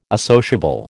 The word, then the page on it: associable: American English